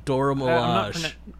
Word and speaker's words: Dora Milaje: It is The Dora Milaje? – Dora Milaje.